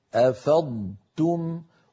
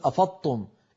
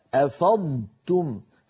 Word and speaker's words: أفضتم: ولا نقول مثلا افضتم في بعض اخواننا يحولون الصوت كله إلى طاء مشددة فيقول أفضتم إيش هذه أفضتم لا يصح يا أخي أفاض يفيض أفضتم فعلينا أن ننطق ضادا صحيحة ساكنة ونتبعها بتاء مضمومة مرققة هكذا أفضتم